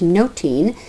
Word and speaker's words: nothing: The word, the Elizabethan word for nothing, was pronounced noting. And so noting and nothing are pronounced the same for Shakespeare.